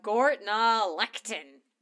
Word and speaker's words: Gortnalechtin: It's funny when the shoe's on the other foot with these Gaelic pronunciations, ain't it? – It is. Gortnalechtin. The field of stone heaps, which is very accurate. – Nailed it in one.